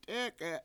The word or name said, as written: t$EEk$e7